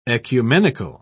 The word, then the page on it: oecumenical: British English